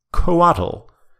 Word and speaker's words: Coatl: Our highest new entry is a debut at number 3, Coatl. That is spelled C-O-A-T-L,